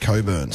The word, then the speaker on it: Cockburn: It's spelt Cockburn, but I think it might be pronounced Coburn sometimes.